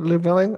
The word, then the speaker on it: Llewellyn: Name, pronounce his last name, Llewellyn, okay.